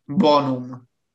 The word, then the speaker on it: bonum: Bonum, it's called. Bonum. – Oh, yeah. – B-O-N-U-M. Bonum. So it's like Boa, you know.